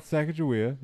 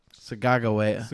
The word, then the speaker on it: Sacagawea: No, you said it's not Sacagawea, it's. – Sagagawea. – Sagagawea. – Interesting. – That's how you say it.